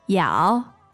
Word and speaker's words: 要: Yao, yao, yao, yao. – Great. And that's the fourth tone, yao.